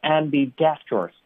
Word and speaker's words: ambidextrous: 很清晰，后面是一个 dexterous ambidextrous 啊，大家呢就不要按着它的字母去读了啊，就它有个自己的一个发音规则了，就叫做 ambidextrous 就完了啊，就那个 x 就发成斯的音就行了啊，dexterous。